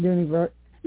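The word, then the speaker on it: Dooney & Bourke: And did I pronounce that right? Is it a Dooney Burke? – Mm-hmm. Dooney Burke.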